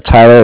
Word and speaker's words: Tyler: Okay, my name is Tyler. It's spelled T-Y-L-E-R, and I'm with Cardiff, C-A-R-D-I-F-F, as in Frank Frank.